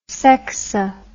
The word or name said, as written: sexo